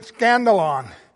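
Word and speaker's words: skandalon: And I'm not making up that word, because the word stumbling block, when you look it up, it is the word that it is pronounced in the Greek scandalon, meaning scandal.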